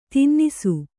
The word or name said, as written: ತಿನ್ನಿಸು